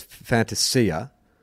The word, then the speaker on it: Fantasia: So look, here's my understanding is that it came out that the actual pronunciation was Fantasia, and then BT has come out and said, yeah, but you Australianize it and make it Fantasia.